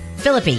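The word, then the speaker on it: Phillippe: Ryan Philip, I never can say his name right. Ryan Philippe? – Ryan what? – Philippi. – There you go.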